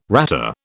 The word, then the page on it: rata: British English